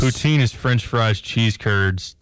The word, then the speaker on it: poutine: Poutine. Is how you say that.